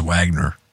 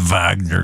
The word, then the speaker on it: Wagner: Let's make the pronunciations correct. The Wagner Group, otherwise known as Wagner, but the Wagner Group.